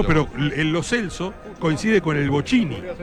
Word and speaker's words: Lo Celso: Hernán Feller hoy tiene, como todos los relatores, cruel desafío de haberse enterado hace 2 horas que es Lo Celso. Porque los jugadores, papel en mano, dijeron ante la cámara de FIFA cómo se pronuncian sus nombres. Y el número 11 argentino dijo Giovanni Lo Celso. O sea que pronunciamos mal su apellido más de 10 años.